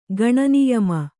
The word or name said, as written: ಗಣನಿಯಮ